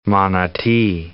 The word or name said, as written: Manati